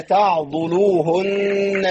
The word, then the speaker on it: تعضلوهن: لا أستاذة زينب، الضاد عليها ضمة، فلا تعضلوهن أن ينكحن.